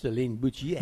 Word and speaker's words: Céline Boutier: And Céline Boutier from France. – Céline Boutier.